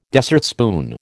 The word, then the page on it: dessertspoon: American English